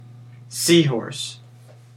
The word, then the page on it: seahorse: American English